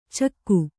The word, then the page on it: ಚಿಕ್ಕೆ: kannada